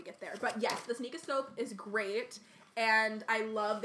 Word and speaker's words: Sneakoscope: A sneakoscope? Or a sneakscope? Whatever. – I know! – Sneakoscope. Yeah, I think I said it right the first time.